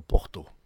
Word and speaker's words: porta: Quand vous avez un mot qui finit par la lettre A, il se prononce O. Et si vous voyez écrit porta, il faut dire porto. Mm. Porto, no porto.